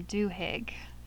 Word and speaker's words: Duhigg: Duhigg.